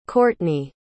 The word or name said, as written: courtney